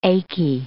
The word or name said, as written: achy